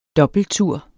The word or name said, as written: dobbelttur